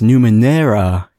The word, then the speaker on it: Numenera: And I couldn't understand why Google kept trying to correct me until I looked at it and I was like, oh, it's Numenera. It's got an E, not an A.